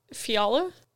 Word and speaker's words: Fiala: Kevin Fiala? – You nailed it right on the head.